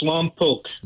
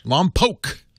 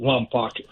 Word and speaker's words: Lompoc: That's Lompoc. – Lompoc. I know, I always say it wrong. Lompoc. – W.C. Fields pronounces it Wompoc.